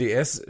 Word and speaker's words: DS: Ähm, und DS spricht sich halt, wenn man es zusammenspricht, wie das französische Wort für Göttin.